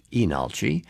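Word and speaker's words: Leenalchi: like Lee Nalchi, but pronounced Enalchi.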